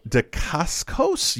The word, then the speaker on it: Dacascos: I have to pronounce these: Jeffrey Lewis, Stacey Travis, and Mark Dacascos.